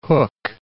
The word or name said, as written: Hooke